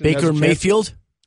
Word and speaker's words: Baker Mayfield: Baker Mayfield? Am I saying his name right? – You are saying it right.